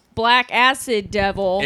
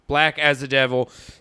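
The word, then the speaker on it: Blackacidevil: Then I read it's supposed to be pronounced like Black as a Devil.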